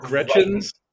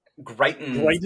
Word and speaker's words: Greitens: Yeah, so has a better grasp on how to say this guy's last name. Eric Greitens. – Greitens. This is my former governor, Greitens.